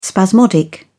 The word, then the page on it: spasmodic: British English